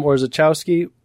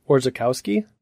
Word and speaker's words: Orzechowski: Tom Orzechowski, which I think we're pronouncing wrong. Orzechowski? – Oh, yeah. Could be.